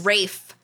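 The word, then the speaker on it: Ralph: It was Rafe, like a rake, but with an F.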